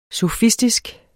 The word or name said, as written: sofistisk